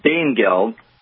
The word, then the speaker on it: Danegeld: Tell me about Dan Geld. – Yeah. Well, I pronounce it Dane Geld. – Dane Geld, okay. – Because it's spelled that way.